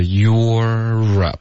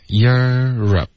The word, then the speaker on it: Europe: 欧洲是它标准的发音是 Europe，可是我们可以弱化一点 Europe。